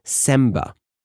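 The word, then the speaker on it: Sembah: And then he performed the first, I think it's pronounced Semba. It's S-E-M-B-A-H, according to Wikipedia.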